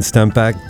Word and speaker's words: Stempeck: I pronounced that correctly, Stempeck? – You got it.